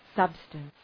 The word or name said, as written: substance